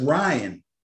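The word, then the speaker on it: Rhyne: Ryan Howard, team. – Ryan? You said Ryan? – Yes, Ryan. – Ryan is a girl. – Yes. R-H-Y-N-E, I think is how she pronounces it.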